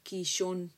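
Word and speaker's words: Kishon: Investigué y Cisón es un río que en hebreo se llama Kishón. Se escribe con K de kilo y latina S-H-O-N.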